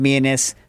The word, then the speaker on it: Mianus: The mayor of Mianus. Hmm. For those of you that know, I think it's actually pronounced Mianis, but it's like a little townlet.